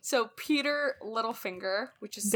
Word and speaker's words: Petyr: It's supposed to be pronounced Peter, but he says Petyr, and it drives me insane.